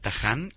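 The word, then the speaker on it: Tahhan: @ Nacho Taján, y mi apellido se escribe con doble H-T-A-H-H-A-N.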